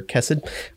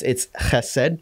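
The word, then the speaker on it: chesed (חֶסֶד): It's spelled, I mean, it's transliterated like C-H-E-C-E-D. So some people call it chesed or kesed, but it's chesed.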